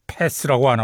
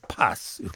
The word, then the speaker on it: Pass: 영국 친구들답게 패스라고 안 하고 파스 이렇게 발음하는데요. 자, Greatest One Hit Wonders.